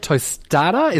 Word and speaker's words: tostada: We've got a beef tostada.